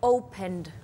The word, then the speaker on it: opened: Und dann wird auch das ED weich ausgesprochen. Also hier wäre es? – Das wäre dann opened.